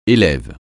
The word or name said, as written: élève